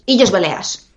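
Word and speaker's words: Illes Balears: Realizaste biología en la Universidad de Les Isles Balears. No sé si lo he dicho bien. ¿Bien? – Sí, Illes Baleares Perfecto.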